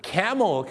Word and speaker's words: camel: องออกเสียงว่า camel ครับผม camel ไม่ใช่คาเมลนะฮะ